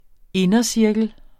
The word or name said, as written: indercirkel